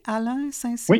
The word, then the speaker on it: Alain Saint-Cyr: Est-ce que c'est comme ça qu'il faut le prononcer, Alain Saint-Cyr, oui.